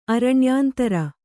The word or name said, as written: ಅರಣ್ಯಾಂತರ